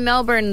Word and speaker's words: Melbourne: I love Melbourne.